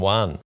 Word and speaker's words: Quan: Don Juan. For people not familiar with the Vietnamese language, Juan is a male name spelt Q-U-A-N, but pronounced similarly to Juan, like in Spanish or Filipino for that matter.